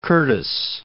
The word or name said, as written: Curtis